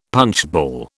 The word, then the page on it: punchball: British English